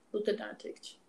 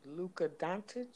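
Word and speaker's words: Dončić: Luka Doncic. – Luka Doncic.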